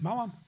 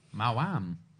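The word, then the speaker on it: Maoam: How would you say that? Do you say Mom or Mound? – Mauam. – Mauam. – That's how they're pronounced. It says it on the advert.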